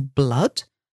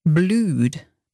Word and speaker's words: blood: So blood, that's spelled B-L-O-O-D. You might think this word would be pronounced blued, but it's blood, to rhyme with wood or good.